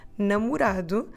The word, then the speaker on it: namorado: So the word for boyfriend is namorado. Okay, it's a bit long. Namorado. Namorado. Let me spell it for you. N-A-M-O-R-A-D-O. Namorado.